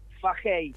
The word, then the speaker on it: Fahey: Fahey Fajey.